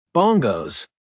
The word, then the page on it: bongos: American English